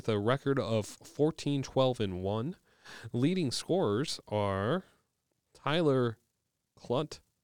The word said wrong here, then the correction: Klute: Clute. Like rhymes with flute. – Oh, that's easy now. – All right. – Okay. Tyler Klute.